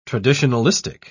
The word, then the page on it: traditionalistic: British English